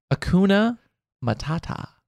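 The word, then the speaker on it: Hakuna Matata: Circle of life would have been more kuma matata, but I'm sorry, kuma matata. – Akuma da Matata? – Not even closer. – But it's Akuma da Matata. How do you say it? – Akuna Matata.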